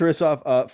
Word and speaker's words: Hristov: It's Hristov. The H is just, you know, it's a very strange syllable in this case, like Hristov, and that's it.